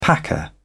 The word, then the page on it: packer: British English